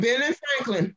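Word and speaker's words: Ben Franklin: But Benjamin, am I saying that right? Benjamin Franklin Drugstore. – Yeah, Ben and Franklin.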